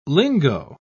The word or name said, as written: lingo